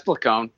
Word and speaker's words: Placone: It's Placone, but Ron.